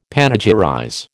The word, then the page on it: panegyrize: American English